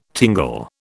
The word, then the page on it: tingle: British English